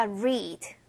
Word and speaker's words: read: 읽다에 해당하는 동사 read 과거형으로 바꾸면 똑같이 생겼는데 발음은 다르죠. read.